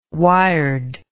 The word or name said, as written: wired